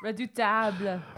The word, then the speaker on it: Redoutable: So the two movies that we are going to be talking about today is Entebbe and Redoable. – Oh, Jesus. Redoutable. Oh, I'm even saying it wrong.